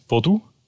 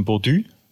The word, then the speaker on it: Bodu: Man spricht ihn so aus, wie man liest, Bodu. Es ist aber sehr verwirrend, weil die Franzosen sagen dann Bodu, weil es U natürlich als Ü aussprechen.